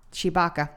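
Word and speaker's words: Tshibaka: Yeah. Shibaka. Yeah, that's how you pronounce it.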